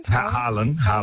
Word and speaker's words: Haaland: Haaland? – Haaland.